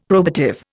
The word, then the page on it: probative: Indian